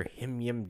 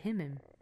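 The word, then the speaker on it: HIMYM: and your him-yim deep cuts. – Him-yim. I don't pronounce it like that. I pronounce it him-im.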